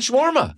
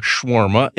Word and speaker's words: shawarma: And that's why I'm thinking I have that shawarma. – And I learned that I'm saying it wrong. Shawarma. It's shawarma.